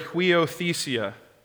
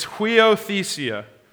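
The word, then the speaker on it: huiothesia (υἱοθεσία): Adoption as sons, it's one phrase, and it's in your notes, and it's the word huiothesia. I practiced that in front of the mirror for like 10 minutes to make sure I said it right. But it's huiothesia.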